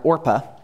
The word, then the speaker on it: Orpah: There are many I can mention, but one of the most popular modern-day stories we love is that of Oprah, who was actually born Orpah, by the way, a character from the Book of Ruth, but people kept mispronouncing it Oprah, and so now that's her name.